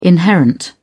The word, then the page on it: inherent: British English